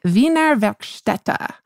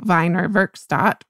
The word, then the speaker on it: Wiener Werkstätte: very nicely that we were mispronouncing the Wiener Werkstätte. So I believe that is the correct pronunciation. We are pronouncing it Wiener Werkstatt, which is not correct, but is how I've been pronouncing it forever, April.